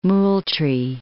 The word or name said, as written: Moultrie